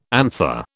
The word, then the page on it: anther: British English